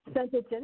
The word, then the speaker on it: Saint Etienne: is um, Say Saint Etienne. Did I pronounce that right? – Saint Etienne, that's correct.